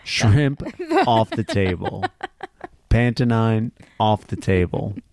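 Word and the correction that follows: Panettone: I guess it's actually pronounced Pantatoni.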